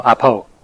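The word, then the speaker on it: apo (ἀπό): The Greek word, it's a three-letter word, apo, apo is pronounced, and it means to keep away from the time or the place or any relation with the event that's being spoken of.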